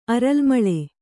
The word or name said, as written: ಅರಲ್ಮೊಲೆ